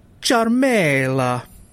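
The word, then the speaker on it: Ciaramella: So I'm going to say it exactly as I learned how to pronounce it from Google. So his name is Eric Charmela.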